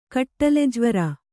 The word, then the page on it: ಕಟ್ಲೆಜ್ವರ: katḷejvara